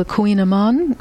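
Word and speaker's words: Kouign-amann: But then there's a lot of totally new things, like on the cover, the pastry that's called the Queen Amon. I think of it as the queen of pastry, because if you look at the spelling, K-O-U-I-G-N, you'd never pronounce it that way.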